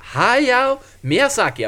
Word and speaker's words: Hayao Miyazaki: Ha, Hayao Miyazaki, jag ber om ursäkt för uttal.